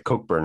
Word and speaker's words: Cockburn: I'm like, how do you spell his, like, how do you pronounce this dude's last name? Like, is it Cockburn? Is it Cockburn? What is this? And it's like Cockburn.